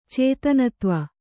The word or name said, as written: ಚೇತನತ್ವ